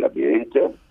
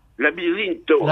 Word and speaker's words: laberinto: En los años 80 mm, ha habido una experimentación con ratas que han metido un laberinto. Esas ratas, cuando han tenido eh, el conocimiento del laberinto, la palabra eh, la pronuncio bien, laberinto. – Laberinto. – Labirinto.